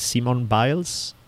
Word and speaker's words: Simone Biles: do you say her name is Simone Biles? – Simone Biles, okay.